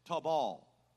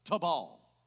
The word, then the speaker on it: tawbal: Maybe John can help me, but it's tabal. T-A-W-B-A-L with the emphasis on the second syllable. Tabal.